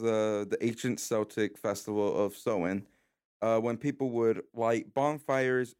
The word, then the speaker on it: Samhain: It's spelled like really weird. It's spelled Samhain, but it's pronounced Samhain.